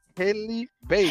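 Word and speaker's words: Halle Bailey: Hayley Bailey.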